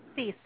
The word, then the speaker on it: Theiss: Theiss, that's correct.